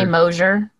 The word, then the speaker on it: Mosher: I say Mosher. More like a Z, but okay.